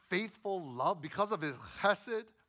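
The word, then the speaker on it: chesed (חסד): That word faithful love in Hebrew, it's chesed. You kind of got to huck up a loogie to say it correctly. Chesed. That's how you know you're doing it right, when you got to spit after you say it.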